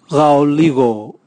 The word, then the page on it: gaoligo: Raga